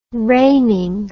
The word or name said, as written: reigning